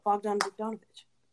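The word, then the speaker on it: Bogdan Bogdanović: Oh, Bogdan Bogdanovich.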